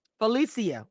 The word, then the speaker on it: Phalisia: Phalisia.